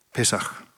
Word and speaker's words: Pesach: Ska jag uttala rätt. Pesach.